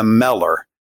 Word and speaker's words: Mellor: He would say, boys, let me tell you what it means to be a Mellor. And that's my last name, which is spelled a little bit different. It's M-E-L-L-O-R.